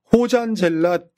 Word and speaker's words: 호잔젤라: 발음이 좀 어렵긴 한데 저쪽 그 남미 쪽 발음은요. 호 아니면 로로 발음되잖아요. 호마리오? 로마리오 뭐 이런 식으로 있잖아요. 네, 네. 호나우드 이런 식으로. – 아, 호나우드. – 그러니까 호잔젤라라고도 발음하기도 하고 다음에 로장젤라라고도 발음하기도 합니다.